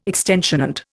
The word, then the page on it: extant: Indian